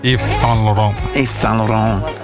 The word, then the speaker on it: Yves Saint Laurent: Ahora, Yves Saint Laurent, o no sé cómo se dice, Yves Saint Laurent. – Yves Saint Laurent.